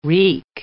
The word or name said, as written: wreak